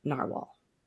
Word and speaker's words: Narwhal: And I know that they've changed the spelling of their name, but I believe it's still pronounced Narwhal.